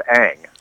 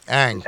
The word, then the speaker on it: Ng: I mean, there was I think John Heyman keeps saying about, you know, their interest in Kim, and I think it's pronounced Ang. – Ang.